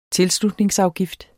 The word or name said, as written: tilslutningsafgift